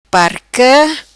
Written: Parca

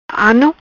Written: Ano

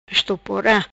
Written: Estopora

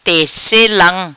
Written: Tece Lam

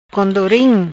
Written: Condorim

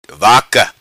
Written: vaca

